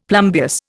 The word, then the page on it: plumbeous: Indian